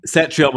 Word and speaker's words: Cetrion: There's a Sassy Sage esque character named Cetrion, actually, in the most recent Mortal Kombat game. – Cetrion with a soft C.